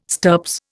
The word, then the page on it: stirps: Indian